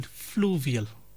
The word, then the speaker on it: fluvial: Well, the word that we were looking for, as I said, alhamdulillah, 3 correct answers today, is the word fluvial. Fluvial. It's spelled F-L-U-V-I-A-L. Fluvial refers to the processes or the landforms, anything associated with the flowing of a river or a stream.